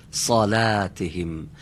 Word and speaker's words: صلاتهم: در کلمه صلاتهم صاد درشت باید تلفظ بشه، ولی لام نازک. صلاتهم اگه بگیم صلا غلط میشه.